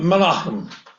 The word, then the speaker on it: Menachem: We call him Menachem. Why do we call him Menachem? Because that's the way his namesake pronounced it when he was prime minister of Israel.